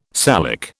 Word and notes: Salic: British English

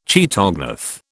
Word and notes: chaetognath: British English

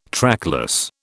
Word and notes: trackless: British English

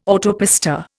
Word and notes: autopista: Indian

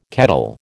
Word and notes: kettle: American English

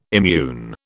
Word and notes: immune: British English

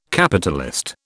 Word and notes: Capitalist: British English

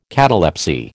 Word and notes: catalepsy: American English

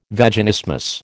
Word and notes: Vaginismus: American English

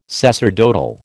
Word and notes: sacerdotal: American English

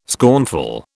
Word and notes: scornful: British English

